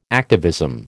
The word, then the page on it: activism: American English